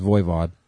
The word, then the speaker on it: Voivod: Will I lose metal points if I ask if I pronounce that right, Voivod? – Oh, that's correct. – That's right. – You won't lose metal points, Voivod.